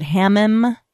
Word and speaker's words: hammam: Is it hammam? – Yes.